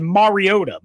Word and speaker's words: Mariota: Say it right, Mariota.